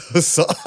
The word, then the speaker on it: Hasan: And then, so the second letter, my name is spelled H-A-S-A-N. Easy. Right? Easy. So it is H. And then this A is like a hybrid between like an A and an F.